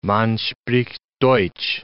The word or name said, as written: man spricht Deutsch